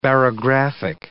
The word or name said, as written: barographic